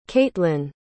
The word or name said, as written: kaitlan